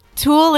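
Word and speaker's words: tulle: Tool is a kind of fabric, like a tutu fabric. – Oh, tooly. – No, you don't say the E. You just say tool.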